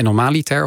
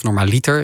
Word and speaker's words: normaliter: En normaliter, of normaliter, ik leg altijd die klemtoon verkeerd, tot mijn spijt, mijn broer wees me pas geleden op.